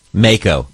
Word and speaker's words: mako: Mako.